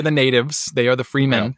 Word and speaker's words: Fremen: Is it the Fremen? How do you pronounce that? – Fremen. – Yeah, they call it Fremen in the movie.